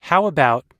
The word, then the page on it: how about: American English